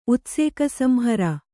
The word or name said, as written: ಉತ್ಸೇಕಸಂಹರ